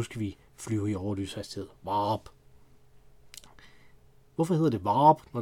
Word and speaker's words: Vorup: Der ligger der en by, som hedder V-O-R-U-P, som man ikke skal udtale Vorup, som var det, jeg, prøvede, jeg gjorde, da jeg flyttede hertil. Men i stedet skal udtale sådan lidt ligesom en herningenser, der bøvser. Varup.